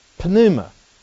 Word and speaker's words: pneuma: But it doesn't start with a n. It starts with a p. And where does that come from? Well, that comes directly out of the Greek, which is pneuma. You see, the Greeks don't have any problems pronouncing the p, pneuma. But we don't like it. So we take the p off. And we just pronounce it new. And we say, oh, well, the p is silent.